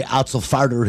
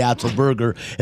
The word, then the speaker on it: Otzelberger: I will not mispronounce his name anymore. He will no longer be TJ Otzelfarter. He'll be TJ Otzelberger as long as they keep winning.